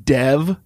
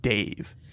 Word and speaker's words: Dev: And his name is spelled D-E-V. – Yes. – We're both wrong because the vowel in it is a lot like Dave, but the D is softer.